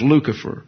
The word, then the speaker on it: Lucifer: That's L-U-C-I-F-E-R. In the Latin, the C's get K sound, so it's Lucifer. And then it comes in the English, and you pronounce it a soft C, Lucifer.